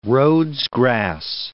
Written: Rhodes grass

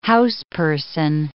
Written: houseperson

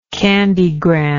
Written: candygram

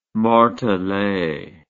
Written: martele

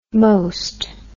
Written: moste

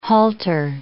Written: halter

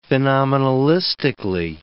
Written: phenomenalistically

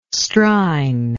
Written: strine

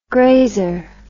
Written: graser

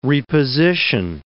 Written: reposition

